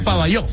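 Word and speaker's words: Pavajeau: En el Armando Maestre de Pabajau. – Pabayó. – Es Pabayó. – Sí, se pronuncia Pabayó.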